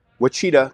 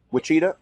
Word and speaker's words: Wichita: Wichita, Kansas. Is that how you pronounce it? Wichita? No, Wichita.